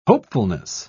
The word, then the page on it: hopefulness: British English